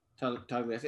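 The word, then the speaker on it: Tattaglia: The G is silent. – Tagalias.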